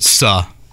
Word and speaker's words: Suh: He pronounces it Suh, just to be clear on this.